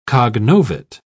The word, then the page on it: cognovit: British English